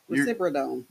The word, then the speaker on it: risperidone: The risperidone, how do you say that? – Risperidone.